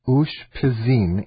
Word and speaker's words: Ushpizin: It's called Ush Pizin, and that is spelled U-S-H-P-I-Z-I-N.